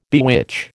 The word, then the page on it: bewitch: American English